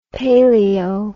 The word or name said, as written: palaeo